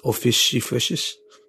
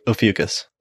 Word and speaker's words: Ophiuchus: Ophiuchus. – Ophiuchus. – Ophiuchus.